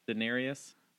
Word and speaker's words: Daenerys: Daenerys? – Yes.